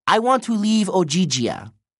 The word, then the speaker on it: Ogygia: Ogygia. – The girl pronounced it slowly, as if Leo were 5 years old. – I want to leave Ogygia, he said.